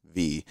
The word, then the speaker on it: vie: What does V mean? Yeah, so it's spelled V-I-E, and it's a French word that means life, and I always tell people, you know, like, c'est la vie.